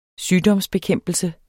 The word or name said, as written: sygdomsbekæmpelse